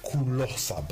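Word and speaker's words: Ku Lo Sa: Yeah, it's pronounced Kulosa, but I think the word that we're talking about here is closer.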